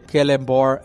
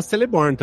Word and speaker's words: Celeborn: Porque eu não sabia que Kellenbor era Kellenbor. Pra mim era Celeborn também.